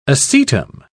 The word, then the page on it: acetum: British English